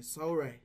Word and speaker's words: Solray: Like all you gotta do is put in my name, Solray.